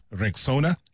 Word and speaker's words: Rexona: It is pronounced Rexona.